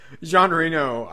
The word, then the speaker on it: Jean Reno: Not Jean Reno. I'm assuming I'm saying that right. He's French. – Mm-hmm. Okay, so you got it perfectly.